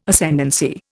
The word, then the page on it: Ascendancy: Indian